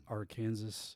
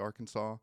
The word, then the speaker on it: Arkansas: Isn't it Arkansas? – No, it is Arkansas.